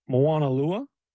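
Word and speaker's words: Moanalua: Moana Lua.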